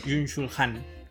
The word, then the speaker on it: Byung-Chul Han: Byung-Chul Han.